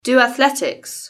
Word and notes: do athletics: British English

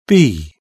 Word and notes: bee: British English

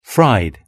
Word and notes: fried: British English

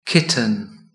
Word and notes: kitten: British English

